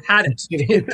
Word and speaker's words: Hadot: That's Hadot for the listeners who might not understand how French is pronounced and written. – Pierre Hadot, right? Is that weird? – Yeah, had it. Pierre had it.